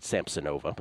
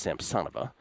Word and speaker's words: Samsonova: I used to pronounce it Samsonova, but I'm told it's Samsonova.